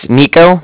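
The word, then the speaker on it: Niko: Yeah, it's Nico. That's N-I-K-O. And then the Bank of Cardiff is spelled C-A-R-D-I-F-F, as in Frank Frank.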